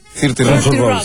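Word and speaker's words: 30 Rock: Y alguna que otra sí que agarro, o Church and Rock. – Thirty Rock.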